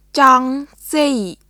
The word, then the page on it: ចង់ស៊ី: チョン・シー; cɔŋ siː